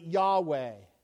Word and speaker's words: Yahweh: Now, you and I, we're English, so we've added an A and an E to it, and we pronounce it Yahweh.